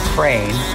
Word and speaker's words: Krein: Crane Shames, C-R-Y-A-N, and then Shames, which is phonetically the same way you would pronounce Tommy Crane's last name, which is spelled K-R-E-I-N.